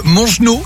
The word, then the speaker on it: Mongenod: Mongenod, c'est comme ça que ça se prononce? – Ok. C'est ça, exactement.